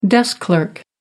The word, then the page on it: desk clerk: American English